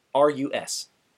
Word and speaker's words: Arcturus: I was just going to say Arcturus. It's like Arctic, but instead of the end of it, it's Urus.